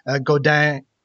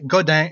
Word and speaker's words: Godin: So usually I say in English, it's pronounced like Godin. Godin rhymes with Rodin. – Rodin. – Like the sculptor.